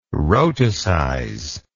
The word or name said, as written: rhotacize